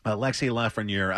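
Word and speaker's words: Alexis Lafrenière: Alexis Lafreniere.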